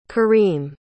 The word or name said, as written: karim